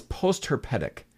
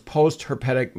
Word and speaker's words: post-herpetic: It's post-herpetic. Herpes, yeah. You know, like herpes. Right. So it's post-herpetic, meaning after the virus.